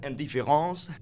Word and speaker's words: Indifference: De naam mag ook op zijn Frans uitgesproken worden. Indifference.